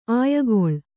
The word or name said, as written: ಅಯಿಗುಳ